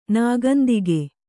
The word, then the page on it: ನಾಗೊಂದಿಗೆ: nāgondige